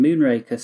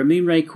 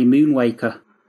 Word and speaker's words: Moonraker: And I was about 9 at the time, and I couldn't say it properly. I'd say it like Jonathan Ross, I'd say Moonwaker.